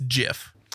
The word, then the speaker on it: GIF: It's pronounced Jif, but it's graphical, like like that. So GIF makes more sense if you're looking at the acronym.